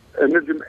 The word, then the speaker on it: Nejim: My name is Sleiman Nejim. – Sleiman. Yeah. Sleiman. Okay, Sleiman. And you pronounce it Nejim? – Nejim, N-E-J-I-M.